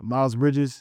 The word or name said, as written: Miles Bridges